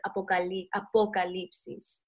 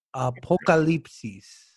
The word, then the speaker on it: Apocalipsis: It's like Apocalypse with an extra is at the end. So it's like eclipse, so the Apocalypse is. But you gotta kind of like pronounce the O with the vowels, the Spanish vowels. So it's like Apocalipsis.